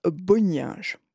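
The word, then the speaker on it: Bonynge: Chacun a sa prononciation, en fait. Moi, j'aime bien dire Boninge. Voilà, Richard, de son prénom.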